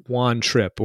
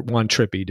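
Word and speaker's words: Juan Trippe: But this is basically a thinly disguised sort of biography, if you will, of Juan Trippi, or Juan Trippi, depending on how you want to pronounce it, who was basically the prime mover in the formation and growth of Pan Am, the airline.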